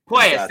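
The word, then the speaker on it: Puelles: But his only loss is to Claudio, how do you pronounce it? Pues? – Oh, Guru's got you. Hold on. Wait. – Pues, there he is.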